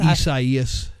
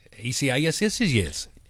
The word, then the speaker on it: Isaias: How you say it again? – Isaias. – Isaias? – Isaias. E-S-A-I-A-S.